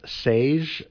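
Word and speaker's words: Säje: Their group is pronounced Sage, rhymes with beige.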